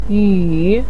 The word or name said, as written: yu3